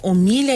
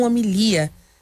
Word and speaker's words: homilia: dizendo que o nome, na verdade, é homilia e não homilia.